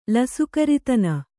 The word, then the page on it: ಲಸುಕರಿತನ: Kannada